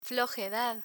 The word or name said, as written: flojedad